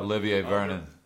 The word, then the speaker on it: Olivier Vernon: Olivera, or how do you say his name? – Who was it? – Olivier Vernon. – Olivier Vernon. Thank you.